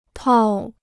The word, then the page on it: 套: tào